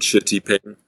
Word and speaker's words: Shitiping: Probably the first really significant one or really beautiful one that I'd be stopping at is a place called Shitiping. Which is spelled S-H-I-T, so like a bad word, I-P-I-N-G.